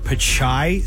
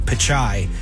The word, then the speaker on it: Pichai: The most he did as far as talking AI was make a joke that the last two letters of his name, Pichai, are AI. Sound like AI.